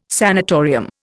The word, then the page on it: sanitarium: Indian